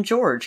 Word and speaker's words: Jorge: Now, she had a boyfriend, George Torres Jr., and I have looked into it because it is spelled more like Jorge. However, I've listened to numerous interviews, and I've even listened to an interview from his younger brother, and I'm going to call him George, because everyone seems to call him George.